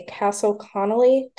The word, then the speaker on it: Castle Connolly: Castle Connolly top doctor. Am I pronouncing that right? – Yes, that's right.